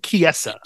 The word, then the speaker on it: Chiesa: I always get his fucking name wrong, but it's Michael Chiesa.